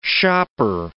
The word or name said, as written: shopper